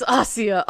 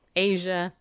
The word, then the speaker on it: Asia: I was like, it's Ossia, Ossia, Ossia. And Janet Parshall proudly said, Asia Beebe, Asia Beebe. And I'm like, it's Ossia Beebe.